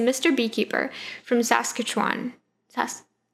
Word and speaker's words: Saskatchewan: Mr. Beekeeper from Saskatchewan.